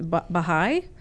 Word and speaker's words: Baha'i: And Diane, tell me a little bit about your upbringing and family members who were, I don't know how to say it, were Baha'i? Is that how yes. – Okay. Who were Baha'i? – Right.